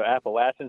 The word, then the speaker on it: Appalachian: You know, the etymology of that comes from a Spanish word, so Appalachian is the correct pronunciation. But there's definitely a regional difference, and, you know, 45% of the trail is in the Northeast, so it's hard to say what the right pronunciation is.